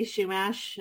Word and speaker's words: Chumash: I say Chumash, but a lot of people will pronounce the H more. But I don't know that there's that it's that off. So you're pretty close.